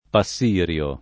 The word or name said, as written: Passirio